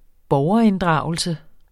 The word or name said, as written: borgerinddragelse